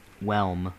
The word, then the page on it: whelm: American English